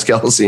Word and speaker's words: Kelce: It's Kelce now.